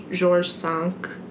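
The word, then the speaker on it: George V: George Sank Hotel. – Mm-hmm. Good pronunciation. – Oh, thank you. – Mm-hmm. – It means George Five.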